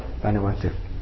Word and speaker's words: Vanuatu: called Vatanao. How do you pronounce it? Vanuatu.